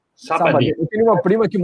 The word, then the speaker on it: Sabadell: é, a pronúncia em catalão seria Sabadell, em espanhol, com dois L no final.